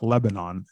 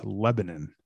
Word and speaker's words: Lebanon: It's not Lebanon, it's Lebanon.